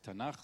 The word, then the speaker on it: Tanach: Und das zusammen, damit man es aussprechen kann, kriegt es ein Vokal. Und das heißt Tanach, sonst wäre das gar nicht durch den Mund äh, passierbar.